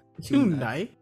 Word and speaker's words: Hyundai: Hyundai. – Ooh, they, good pronunciation. – Actually Hyundai, like Sunday.